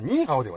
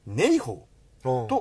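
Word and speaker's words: 你好: 外人でよかった、ところで前回メールを読んでいただいた時に書き始めにはニーハオでとおっしゃっていただいたのですが、実は香港はニーハオではないのです。字は同じなのですが、広東語ではネイホーと発音します。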